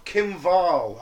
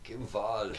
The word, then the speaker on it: Wall: This week we're going to talk about the murder of Kim Vahl, I believe you pronounce it. – Kim Vahl.